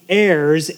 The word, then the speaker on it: heirs: And then we're going to look at the heirs, H-E-I-R-S, but the H is silent.